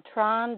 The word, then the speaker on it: Tran: Tran or Tron, doesn't matter.